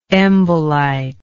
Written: embolite

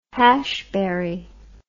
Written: hashbury

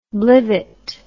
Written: blivit

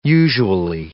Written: usually

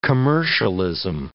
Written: commercialism

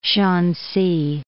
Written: Shansi

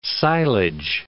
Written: silage

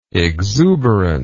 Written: exuberantly

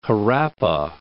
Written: Harappa

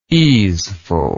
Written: easefulness

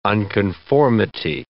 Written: unconformity

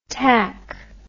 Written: tac

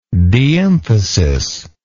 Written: deemphasis

